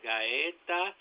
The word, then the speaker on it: Gaeta: The last name is Gaeta. It is spelled G-A-E-T-A.